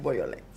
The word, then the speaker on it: Beaujolais: Es, un nombre francés, se escribe completamente diferente, pero su pronunciación es Boyolé.